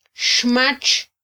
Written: xmatx

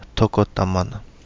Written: Togo Damana

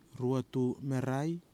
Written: RUETU MERAI

